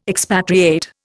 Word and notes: expatriate: Indian

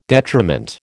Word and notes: detriment: American English

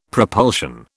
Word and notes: propulsion: British English

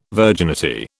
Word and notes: virginity: British English

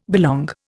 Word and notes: belong: Indian

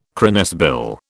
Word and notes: cranesbill: British English

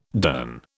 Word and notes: durn: British English

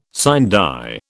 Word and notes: sine die: British English

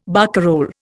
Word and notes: barcarole: Indian

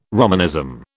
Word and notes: Romanism: British English

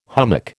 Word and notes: hummock: American English

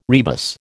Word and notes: rebus: American English

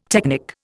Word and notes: technic: Indian

